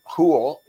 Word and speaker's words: Houle: So my last name, Houle, is spelled H-O-U-L-E.